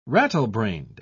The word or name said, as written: rattlebrained